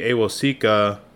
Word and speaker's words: Awosika: And you know, there's some other names like Kayode Awasoka, Kayode Awosika.